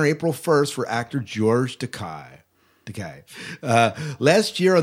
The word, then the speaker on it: Takei: Our favorite Star Trek veteran George Takei, Takei, Takei, Decay. Takei. There we go. R- rhymes with William Shatner's toupee. Yeah, toupee, mm-hmm. toupee.